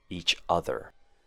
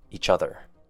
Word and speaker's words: each other: And our last one for pronunciation would be blended words, each other. Start with saying the letter E, and then quickly say chother, which has the short U sound. Each other.